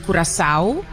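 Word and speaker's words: Curaçao: Yeah, so there's like, and also the name Curacao itself, in Papiamento, you actually pronounce it Curacao. – Curacao. – Which has a Portuguese sound to it.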